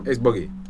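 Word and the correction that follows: Ace Boogie: Am I saying it wrong? – Ace or dude, but Buggy. Ace. Boogie. – Okay, boogie.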